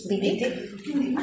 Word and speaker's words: Wittig: ¿Cómo se pronuncia? ¿Wittig? – ¿Wittig? – Así.